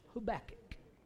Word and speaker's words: Habakkuk: Habakkuk. Did I say that right? – Yes, you did.